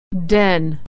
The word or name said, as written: den